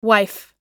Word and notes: wife: American English

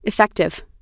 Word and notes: effective: American English

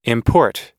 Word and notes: import: American English

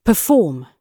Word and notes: perform: British English

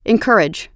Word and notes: encourage: American English

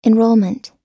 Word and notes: enrolment: American English

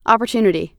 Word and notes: opportunity: American English

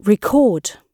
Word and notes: record: British English

